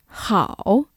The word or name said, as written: 好